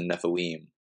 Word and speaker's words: Nephilim: So, but yeah, you know, mainly going back to like the main topic of this is the Nephilim, or in Hebrew, it's the Nephilim. That's how it's pronounced.